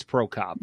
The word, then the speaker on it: Prokop: Luke Prokop. Am I saying that right, Bill? I think it's Prokop. – Yeah.